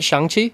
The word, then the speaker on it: Shang-Chi: Shang-Chi? – Yeah, yeah.